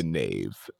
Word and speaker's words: knave: The knave. – Yeah, I love that word, the knave. – Is what is it? – K-N-A-V-E. – Is knave. That how it's spelled? – Yeah, it's spelled with a K.